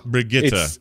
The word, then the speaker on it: Brigitte: Well, She has a name, and we see like a drawing of her in the origin story of Brigitte, which is the way you pronounce it. – Okay, say that again so that everyone can hear that. – Brigitte. It's because she's Swedish, and that is, I mean, I'm sure the Swedes listening to this are going to go like, oh my God, stop making a fool of yourself. But that's more or less how you pronounce it.